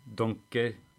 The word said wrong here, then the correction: Dunkerque: Dunkerque. – Dunkerque.